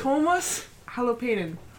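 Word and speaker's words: Tuomas Holopainen: Tomas Halopanian or how do you pronounce it? – Tomas Halopanian. – Tomas Halopanian.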